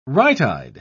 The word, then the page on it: right-eyed: British English